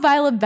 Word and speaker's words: Violet: I'm Via Benson. Oop. Not me not saying my name right.